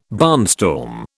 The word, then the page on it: barnstorm: British English